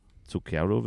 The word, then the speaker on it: Zucchero: Zucchero wird dieser Künstler ausgesprochen.